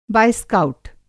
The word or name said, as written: बायस्काउट